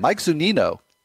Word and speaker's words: Zunino: And Mike Zunino, excuse me, let's try that again. Mike Zunino, that's how you pronounce it, went for $13.